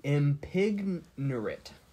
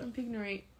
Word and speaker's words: impignorate: Impignorate. – When I saw it. – Impignorate. – You're ignorant. – Ignant. – Impignorate. Impignorate. – Sounds like an ignorant and impregnate. – Mm-hmm. Or impignorate.